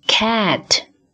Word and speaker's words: cat: cat cat 在发 a 这个音的时候呢，我们很多中国人把它读作 a，比如说 bed have cat a 这个音发的不到位，主要的原因是我们的嘴型嘴巴张的不够大。